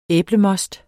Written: æblemost

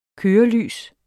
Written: kørelys

